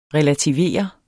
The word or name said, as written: relativere